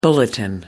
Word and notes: bulletin: American English

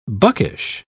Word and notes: buckish: British English

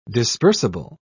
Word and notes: dispersible: British English

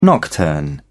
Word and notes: nocturne: British English

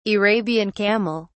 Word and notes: arabian camel: British English

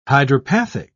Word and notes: hydropathic: British English

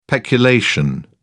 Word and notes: peculation: British English

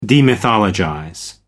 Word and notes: demythologize: American English

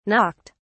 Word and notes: knocked: British English

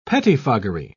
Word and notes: pettifoggery: British English

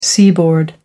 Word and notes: seaboard: American English

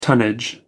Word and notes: tonnage: American English

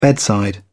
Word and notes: bedside: British English

